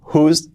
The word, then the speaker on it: whose: W-H-O-S-E, WOSE, la pronunciación whose, whose, y no quiere decir otra que de quién, ¿vale?